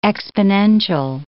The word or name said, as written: exponential